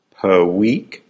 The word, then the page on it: per week: American English